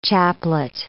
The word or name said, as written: chaplet